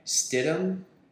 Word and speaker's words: Stidham: Sidham... I don't even know how to say his fucking name. – Stidham. – Okay. – Yes. Both Auburn QBs. – Okay. Stidham was their guy.